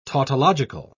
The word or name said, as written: tautological